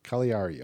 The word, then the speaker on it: Cagliari: How do you say that again? Because I always say Cagliari. Cagliari. Okay, Cagliari.